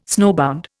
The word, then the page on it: snowbound: Indian